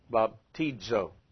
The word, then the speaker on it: baptizo: All right, B-A-P-T-I-Z-O. How would you say this? Baptizo. Accent here, Baptizo. All right, so that's the idea of stress.